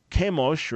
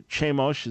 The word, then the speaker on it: Chemosh: Some claim it was the wrath of the god Chemosh, or Chemosh, as many of you might pronounce it, that the Moabite king worshipped and offered a sacrifice to him.